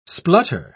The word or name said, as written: splutter